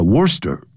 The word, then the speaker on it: Worcester: Then, 然后呢 there are the areas of the Bagrivia Valley, Dumbarville, 还有 Wellington, Worcester, 这个不要念 Worcester, 叫 Worcester, C-E 不发音叫 Worcester, and the Swartland,